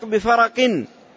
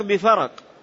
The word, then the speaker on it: فرق: بفرق بين 6، تصدق بفرق، الباء هنا حرف جر، والفرق بفتح الفاء والراء المهملة وآخره قاف، هكذا ينطقه عامة أهل اللغة، وينطقه أهل الحديث بإسكان الراء، بفرق يسكنون الراء.